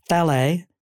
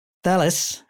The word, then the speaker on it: Thales: in French they say Thalée, sounds like Calais, which is a place in France. And he says, and it sounds like how, and he says in English, it's Thalès, which is how the English say Paris.